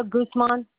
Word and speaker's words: Guzman: Celia Guzman. – Spell that first name for me. – C-E-L-I-A. – G-U-Z.